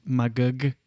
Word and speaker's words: Magog: Magog.